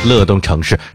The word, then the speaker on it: 乐动城市: 然后点击服务号，上面有个搜索栏，然后在这里面输入乐动城市，乐也是音乐的乐，多音字乐，呵呵呵的那个乐，动动词大词的动，城城堡的城，城市的城，乐动城市是乐动城市的是，哎呀，有人说你还说人家粘牙呢，我跟你讲你也够磨叽的了，就这么两句话呀，你翻来覆去的呀，那字儿你还用掰开了讲吗？